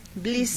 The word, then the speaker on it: Bliss: Bliss.